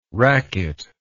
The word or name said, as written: rackett